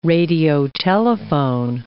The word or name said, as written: radiotelephone